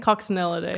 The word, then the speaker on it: Coccinellidae: Coccinellidae.